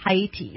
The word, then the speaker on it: Haiti: And is that how you pronounce it? Haiti? – Haiti, yes.